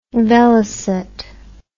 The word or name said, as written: velocette